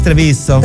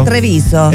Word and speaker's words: Treviso: Yo sé que pronuncia mejor el italiano. ¿Cómo es Treviso? – Treviso. – En Treviso. Oye, sale bien. – Treviso.